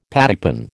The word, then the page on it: pattypan: American English